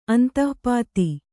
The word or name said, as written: ಅಂತಃಪಾತಿ